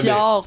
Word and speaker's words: fjord: Ben, si tu fais juste le lire, tu sais pas nécessairement comment ça se prononce. – Fjord. – Parce que ça, c'est un mot qui vient du norrois, qui est d'une langue scandinave. Fait, évidemment, c'est FJ, là, OK.